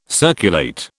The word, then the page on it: circulate: British English